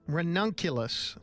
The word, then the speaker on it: ranunculus: I believe it is actually pronounced ranunculus. It's a buttercup.